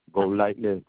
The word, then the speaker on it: Golightly: Tommy, T-O-M-M-Y, Lee, L-E-E, Golightly, G-O-L-I-G-H-T-L-Y.